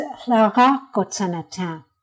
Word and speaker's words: Hlara Kotsenatin: It's Hlara Kotsenatin. And it means learning together.